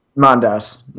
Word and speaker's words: Mondas: Yeah, so it's like, we've the Cybermen we've seen actually mostly, like, recently on the show have been from Mondas, the 10th planet. Tell me if I'm mispronouncing that, because I haven't watched... – It's Mondas, yeah.